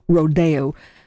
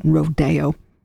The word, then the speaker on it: Rodeo: Both Agnes DeMille, the choreographer, and Aaron Copland always said the title of this ballet with the accent on the second syllable, Rodeo. So when you wonder why we don't say Rodeo when we introduce the piece, it's standard practice that DeMille and Copland always said Rodeo.